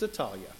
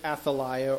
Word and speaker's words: Athaliah: but it's most likely pronounced Atalia rather than Athaliah or something along those lines.